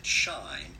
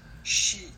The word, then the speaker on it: Shein: Many people think and do pronounce it shine, but this is actually a made up word made with the words she and m.